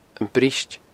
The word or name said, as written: briost